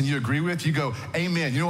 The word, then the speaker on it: amen: But here in Texas, we say it wrong, right? We say amen. But really, if you're to be a Greek speaker, or you Spanish speakers, you do say this correctly, amen.